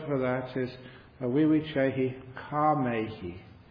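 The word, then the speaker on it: kāmehi: Kamehi, K long A M E H I. And the reason I have to spell that out is it's nothing to do with karma. It's nothing to do with the action and the law of karma.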